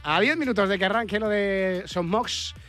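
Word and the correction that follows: Son Moix: Sonmosh, que me, enseñó Sara el otro día a pronunciarlo bien, Sonmosh Pues vamos a ver qué ha dado de sí el partido.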